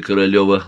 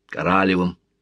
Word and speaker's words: Королёв: Принимая Королёва, лагерный лейтенант, сверяя по списку фамилию, назвал его Королёвым, причем сделал ударение на втором слоге, Королевым. «Королёв», — поправил Сергей Павлович, — «у нас на могилах фамилии не ставят», — огрызнулся лейтенант.